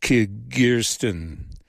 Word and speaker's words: Kyrgyzstan: In Central Asia, Kazakhstan, how do we pronounce this one? Kyrgyzstan, that's right.